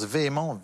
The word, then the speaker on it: VHEMT: C'est la VHEMT, ça se prononce véhément, Vremont.